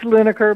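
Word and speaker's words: Linacre: Now, am I pronouncing this correctly, Lineacre Quarterly, or is it Lineacre Quarterly? – It's Lineacre, but either works.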